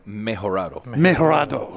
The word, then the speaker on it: Mejorado: And the filler is a Nicaragua, Condega, and Esteli, and Dominican Republic, Piloto, and Majorado. Did I say that right? – Mejorado.